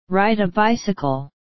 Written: ride a bicycle

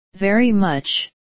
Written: very much

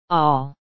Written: all

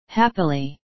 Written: happily

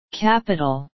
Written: capital

